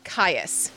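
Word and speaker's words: Cyas: I'm going to say Caius, a hard C. C-Y-A-S, Sister Caius.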